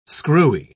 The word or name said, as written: screwy